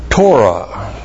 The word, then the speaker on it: Torah: Genesis, the part of the Torah. T long O R A H.